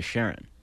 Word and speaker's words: Charon: So, but the discoverer always pronounced it as Charon, and he did it specifically because his wife's name was Charon.